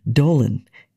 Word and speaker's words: Dowland: The surname of lutenist and composer John Dowlin had a slightly different pronunciation, Dolan, in Elizabethan English, as attested by a piece that exists in versions for both solo lute and string consort, Semper Dolan, Semper Dolens, Always Dowland, Always Doleful.